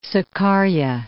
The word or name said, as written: Sakarya